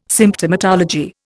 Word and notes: Symptomatology: Indian

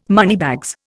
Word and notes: moneybags: Indian

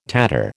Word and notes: tatter: American English

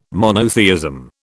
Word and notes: monotheism: British English

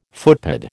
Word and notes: footpad: American English

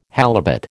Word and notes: halibut: American English

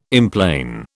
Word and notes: emplane: British English

